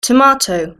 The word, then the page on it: tomato: British English